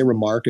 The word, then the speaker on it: RMRK: Am I saying it right? If I say Remark, is that, am I? – Yes, exactly.